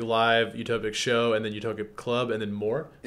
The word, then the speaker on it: Utopic: Uto- it's pronounced utopic, right? Utopic. Okay, I was like, I don't know how I'd butcher that.